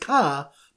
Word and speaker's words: car: So the Siman Daf Chafalov is a car pronounced ka by people from Boston.